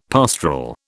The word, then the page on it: pastoral: British English